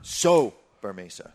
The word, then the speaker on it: Sobremesa: Because this one's pronounced Sobremesa.